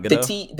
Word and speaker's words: Gadot: Oh, it's not Gadot? – The T is hard. – Oh, the T is hard. – The T is not silent.